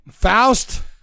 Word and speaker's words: Faust: The guy's name rhymes with Roust. – All right. Faust.